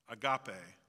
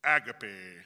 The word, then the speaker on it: agape: And so you'll have to forgive me if I mispronounce it, because in his thick British accent, he pronounces it agape, agape.